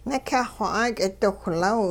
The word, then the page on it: nikyah-xw 'aak'idiwhlaw: Hupa